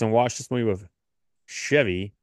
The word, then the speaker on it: Chevy: You don't say it like the car. It's Chevy.